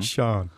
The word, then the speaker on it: Sian: Is that S-I-A-N? – Yeah. I was saying it wrong. – Shan. – I say it Shan.